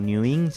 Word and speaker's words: N3WIN: Salimos como NewIn, se escribe N3WIN. – Espérate, porque yo soy vieja escuela. Espérate. N3, en lugar de la E, un 3. – Ajá, N3W. – N3W. – IN. – IN, NewIn.